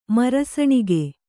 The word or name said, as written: ಮರಸಣಿಗೆ